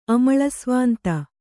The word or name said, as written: ಅಮಳಸ್ವಾಂತ